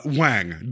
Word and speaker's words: Wange: You could give them any last name, and you write Wang, W-A-N-G-E. Like, how do you pronounce that? Wangy? – I'm like, well, I don't want to say Wang on here, but I guess it is. But Wang, but the E is silent, you know.